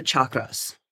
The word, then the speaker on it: chakra: First of all, what are the chakras? Okay, or the chakras. Chakra is how you pronunciate it. That's how you pronounce it in Sanskrit.